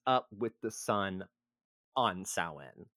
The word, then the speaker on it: Samhain: Samhain, which is not pronounced Samhain.